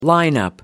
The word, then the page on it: line up: American English